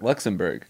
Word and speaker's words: Luxembourg: You're saying Luxembourg wrong, too. – Borg? – It's Berg. Luxembourg. – Yeah. – I'm pronouncing it B-O-U-R-G.